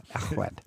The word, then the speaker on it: Llechwedd: L is pronounced L, but two Ls are pronounced... – Ech. – Llechwedd.